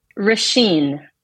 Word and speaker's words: Roisin: Say it one more time for me. – Rasheen.